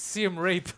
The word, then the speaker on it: Siem Reap: He's mispronouncing it. It's Siem Reap.